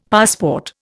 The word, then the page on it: passport: Indian